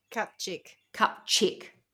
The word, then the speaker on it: Kupczyk: During her convalescence, she was treated by a pretty 29-year-old nurse named Olga, and I think it's pronounced Kupczyk. You'll know, Schmitty, if I tell you if it's spelt. It's spelt K-U-P and then C-Z-Y-K. – Kupczyk. – Okay, that's Kupczyk, Olga Kupczyk.